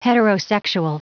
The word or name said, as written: heterosexual